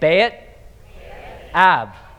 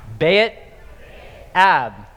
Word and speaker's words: Beit Ab: It was centered around what is called the father's house, and in Hebrew that's called the Beit Ab. So let's say it, Beit Ab. Beit Ab. So that's spelled B-E-T and then A-B. So Be'et-Ev, Father's house.